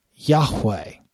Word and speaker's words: Yahweh: This is going to be the approximate pronunciation in Moses' day. It would have been Yahweh.